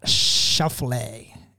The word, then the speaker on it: Schauffele: but I'm a big fan of Xander Schauffele.